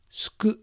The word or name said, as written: Suku